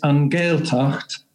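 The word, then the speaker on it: An Ghaeltacht: Gaeltacht? – I regret to say the pronunciation is Angaeltacht, and these are Irish-speaking communities in the west of Ireland.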